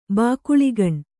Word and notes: ಬಾಕುಳಿಗಣ್: Kannada

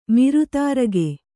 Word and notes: ಮಿರುತಾರಗೆ: miru tārage

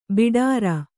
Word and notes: ಭಟಾರ: bhaṭāra; Kannada

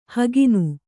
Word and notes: ಹಗಣ: Kannada

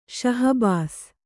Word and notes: ಶಾಹಬಾಸು: śahabāsu

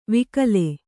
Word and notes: ವಿಕಲೆ: vikale

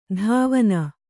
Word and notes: ಧಾವನ: dhāvana

